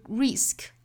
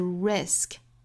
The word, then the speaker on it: risk: 我们注意两个音素组合在一起，这个辅音连缀发的是 gray 很快过渡到后面的双元音上 greater 那英音里面的这个 t 的发音是爆破明显的 greater 后面的 risk 注意这个元音的口型不要过紧不是 risk 而是 risk 我们说到这个松元音 a 的发音呢上下之间的这个间距啊，大概是一个食指，所以我们在发这个 a 的时候，找不好度的话呢，就可以拿食指这样去在上下齿之间比划一下。